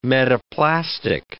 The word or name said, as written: metaplastic